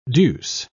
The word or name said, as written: deuce